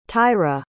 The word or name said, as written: tyra